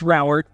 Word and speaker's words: Rauert: It's Rauert, like flower.